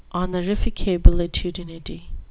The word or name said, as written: honorificabilitudinity